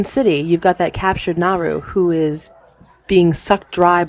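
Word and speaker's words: Naaru: The cool thing is here is the Naru, is how you pronounce it,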